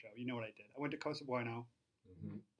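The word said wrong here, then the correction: Cosa Buona: Bueno is Spanish, and Buona is Italian.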